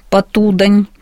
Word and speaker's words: Потудань: Все-таки, когда Платонов вернулся в литературу в 34-м году, он сумел издать книгу своей прозы под названием «Река Потудань». Ну, в обиходе часто говорят «Потудань», но это реальная река, и местные жители ставят ударение именно так, Потудань.